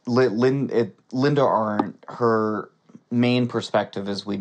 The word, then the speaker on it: Arndt: Is that how you say it? Linda mm-hmm. Arndt? – Arndt, yeah.